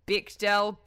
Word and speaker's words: Bechdel: It's like Bechdel.